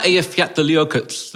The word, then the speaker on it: Eyjafjallajökull: So is that Eyjafjallajökull that was the one that caused all the problems with the cloud earlier? The one that people call unpronounceable, but it's Eyjafjallajökull.